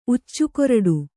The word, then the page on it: ಉಚ್ಚುಕೊರಡು: uccukoraḍu